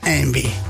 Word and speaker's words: Yngwie: No, Yngwie. – No, si lo buscas escrito es imposible. Y-N-G-W-I-E. – Ah, imposible. – Por eso. Pero se pronuncia Envy.